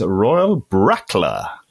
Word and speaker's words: Royal Brackla: Nej, nu ska vi prata, eller vi, David ska få prata i tre minuter om eh, det här destilleriet som jag tror uttalas Royal Brackla.